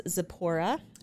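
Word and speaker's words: Tzipora: Not the silent T? – No, because it's actually, if you've ever read Night by Elie Wiesel that's about the Holocaust, his sister's name was Zipporah. So I don't know if it's like a Jewish, but they lived in Germany. So I don't know if it's German, whatever it is. But anyway, so Zipporah would be correct.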